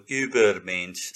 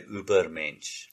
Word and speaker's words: Übermensch: It says, Nietzsche's concept of the Übermensch, I believe that Über or Übermensch, something like that is the proper pronunciation. But pardon me, I'm going to use my South African pronunciation and I'll just say Übermensch.